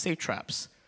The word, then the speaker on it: satraps: I thought it was called satraps, but it's actually pronounced satraps. S-A-T-R-A-P-S.